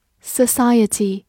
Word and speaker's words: society: Society, S O C I E T Y society，s o 发 s a s，c i 发 s i s，字母 e 发短音 a，ty 读作 t，society，society，它是一个名词，表示社会。